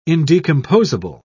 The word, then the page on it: indecomposable: British English